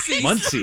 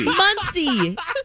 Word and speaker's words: Muncie: Muncie. – Muncie.